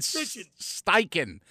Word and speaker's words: Steichen: Offensive coordinator Shane Steichen. If there's two vowels together, it's S-T-E-I-C-H-E-N. You always go off the second vowel. You know who taught me that? Zach Wiegert, because his name was W-I-E-G-E-R-T at Nebraska.